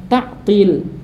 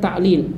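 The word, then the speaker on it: ta'til (تعطيل): Jadi meninggalkan pengucapan dengan ta'til bukan ta'lil dalam halaman 20 itu.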